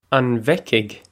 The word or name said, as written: An bhfeicfidh